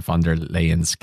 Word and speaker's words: von der Leyen: von der Leyen's couch. Am I saying that right? Von der Leyen? – That's the first time I've heard it read out loud, but yes.